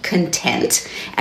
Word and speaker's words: Kontent: Towell's engaging, plucky narrator, who it turns out her name is Katie Content, and her name is spelled, her surname, K-O-N-T-E-N-T, and as she says to someone who meets her and mispronounces her name, that the accent is on the second syllable. The name is Content. As she says, state of mind rather than contents of a book.